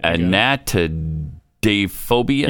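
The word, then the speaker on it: anatidaephobia: A-N-A. – A-N-A. – T-I-D-A-E. P-H-O-B-I-A. So, anatidaphobia? – Yeah, there it is. – Anatidaphobia? – That's a thing. – Anatidaphobia.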